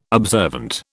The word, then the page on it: observant: British English